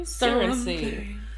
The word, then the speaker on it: Surrency: How do you spell it? – S-U-R-R-E-N-C-Y. – Surrency. – F, so. – Surrency? – Surrency. – Sounds like Surrency.